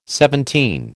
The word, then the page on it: seventeen: American English